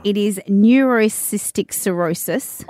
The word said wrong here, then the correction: neurocysticercosis: Oh, great pronunciation. – Neurocystic. – That sounds good. No, it's neurocysticercosis.